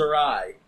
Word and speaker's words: awry: It goes awry. – Yes. – Did I say that right? – Yes.